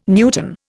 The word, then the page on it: newton: Indian